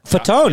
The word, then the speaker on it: Fatone: Joey Fatone? – Fatone. No, you nailed it. Fatone. – Come on, nice. Man. I wasn't sure if you had to emphasize the E. Says he's sometimes the Joey Fatone of the ON group.